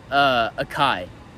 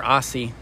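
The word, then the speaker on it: açaí: It's Achi. Or Aussie. My bad. Aussie. – That's Aussie. – How it's pronounced. – Aussie. Mhm. – A C A I.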